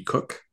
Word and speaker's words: Cooke: Cook. And my name is spelled Shaun and Cook with an E on the end.